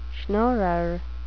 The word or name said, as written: schnorrer